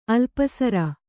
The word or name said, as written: ಅಲ್ಪಸರ